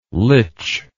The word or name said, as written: lich